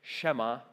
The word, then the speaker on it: Shema: I'm going to say Shema because I looked it up and I listened to it, and that's what it told me.